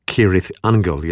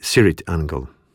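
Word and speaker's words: Cirith Ungol: Poprawna wymowa nazwy to Kirith Angle, jednak członkowie zespołu wymawiają ją z miękkim C na początku, czyli Sirith Angle.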